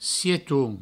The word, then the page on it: sieton: sietoun